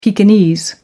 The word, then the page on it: pekinese: American English